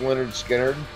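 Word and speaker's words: Lynyrd Skynyrd: Yeah, if you get the original like Pronounced Leonard Skinner, because it comes with a audio and a DVD.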